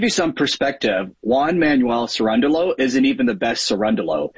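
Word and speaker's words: Cerúndolo: And all of a sudden, his opponent starts winning games, Sinner's hobbling around, and somehow, some way, as a 32-1 underdog, Cirundolu, I believe is how you say his name, beats Sinner. I mean, Cirundolu is how you say his name.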